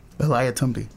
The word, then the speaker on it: Eliathamby: Eliatumbi.